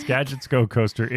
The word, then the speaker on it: Gadgets Go Coaster: Gadgets Go Coaster. – And do you even remember Am I saying that right? – Yeah, it's Gadgets Go Coaster.